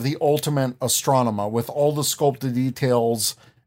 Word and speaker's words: Astronema: Like a little Astronema shrine. – There you go. – No. – Yeah, that was okay. Right. You said it right.